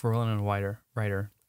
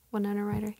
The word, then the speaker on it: Winona Ryder: And then people they're like, oh, we love Winona Ryder. I said that wrong, but. – Winona Ryder, yeah.